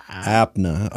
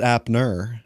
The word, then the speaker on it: Apna: A-P-N-A Bazaar. – Apna. Okay. – B-A-Z-A. – I wasn't sure if it was Apner. – Nope. – And you were just pronouncing nope. – it. – Nope. Apna.